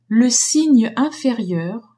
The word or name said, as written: signe inférieur